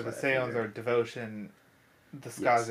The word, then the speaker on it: Skaze: Okay, so they're called either Skaze or Skaze. It's spelled S-K-A-Z-E. – I would definitely say Skaze. Skaze.